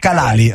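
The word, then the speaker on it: Khalaili: Innanzitutto l'ho pronunciato bene, mi aiuti con la pronuncia? – Kalali, ok.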